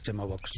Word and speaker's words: Chemobox: Lo primero, ¿cómo pronunciamos correctamente el término? – Chemobox. – Tal cual. Como lo conocemos todos es Chemobox. – Chemobox, que es como se escribe.